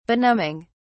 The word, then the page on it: benumbing: British English